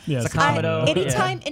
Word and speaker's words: Sakamoto: Sakamoto. – Anytime you put too much emphasis on a Japanese word, you're doing it wrong because they don't do that. That's not a thing in Japanese.